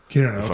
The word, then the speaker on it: Kiruna: Kiruna, I'm saying that correctly. – Yeah, that's very good.